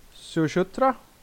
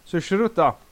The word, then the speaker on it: Sushruta: Ah, du, du är säkert mer kunnig i hur det här uttalas, men Sushruta. – Sushruta var det. Nu sa jag fel. Sushruta. Sushruta. Sushruta. Ja.